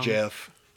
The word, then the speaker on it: Geoff: Jeff. – Oh, Jeff Duncan. – Yeah. – You know him? – Nope. But I know it's Jeff and not Geoff. – It's spelled G-E-O-F-F. Yeah. But that's Jeff.